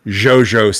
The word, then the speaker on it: Giogio: they Jojo in Italian is spelt G-I-O-G-I-O, which makes a Jojo sound, right?